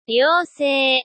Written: ryousei

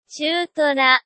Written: chuutora

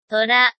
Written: tora

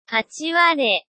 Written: hachiware

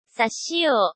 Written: sashio

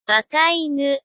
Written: wakainu

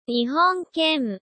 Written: nihonken

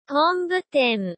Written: honbuten

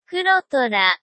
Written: kurotora

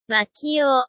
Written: makio

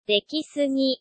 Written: dekisugi

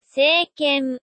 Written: seiken

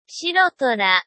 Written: shirotora

